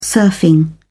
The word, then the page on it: surfing: British English